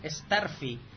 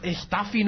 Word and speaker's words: Starfy: ser que la pronunciación oficial es Starfy. Lo cual tiene bastante más lógica. – Sí. Uy, sí, lógica de Curro Jiménez, por lo menos. – Dios, Staffy no se llama más Staffy.